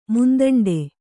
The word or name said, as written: ಮುಂದಂಡೆ